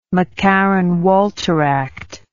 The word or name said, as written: mccarran walter act